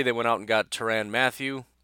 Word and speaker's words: Tyrann: I know I was told by a listener that that's not how you say it, but I looked it up, and they say you're supposed to say it like Byron, but it's with a T. That's what Google says, so his name is now Tyrann.